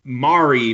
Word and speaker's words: Mari: So her name is Anna, but it's A-N-A. And then it's M-A-R-I. It's pronounced like calamari. Mari. Like, so Anna Mari, and it's Kause, her last name.